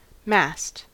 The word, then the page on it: mast: American English